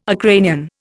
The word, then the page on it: Ukrainian: Indian